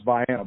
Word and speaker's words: Vienna: Or what, you know, non-locals, how you should just call Vienna, which is not, it's not pronounced that way. Up here it's pronounced Vienna, but it's named for Vienna, Georgia.